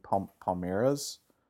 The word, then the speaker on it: Palmeiras: I'm going to mispronounce the Brazilian team, Palmeiras. – More nasal. You got to go more nasal.